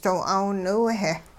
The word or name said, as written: doo 'aawniw-heh